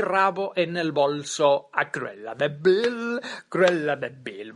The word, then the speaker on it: Cruella: A ver, que se pronuncia Cruella de todas formas, pero lo que tiene el inglés, ¿no? Dos L's, una L.